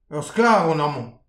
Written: ur sklav on aman

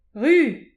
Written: ruz

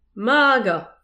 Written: magan

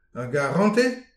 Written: ar garantez

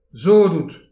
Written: sot out

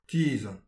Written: tizhan

